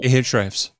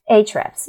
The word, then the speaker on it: Ahrefs: Then I do a keyword research with Ahrefs, or I don't know how to pronounce that actually. – Ahrefs. – Ahrefs, yeah, perfect.